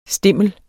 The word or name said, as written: stimle